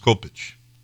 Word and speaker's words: Kopyc: In Polish, it's pronounced Kopich with an accent over the C. It's not been shortened either. The headstones in Poland still have K-O-P-Y-C with an accent over it.